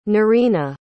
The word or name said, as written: Nerina